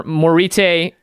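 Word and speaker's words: Moritte: Like, why is the last T and E there if they make no sound? – Morit. Oh, maybe it's Morit.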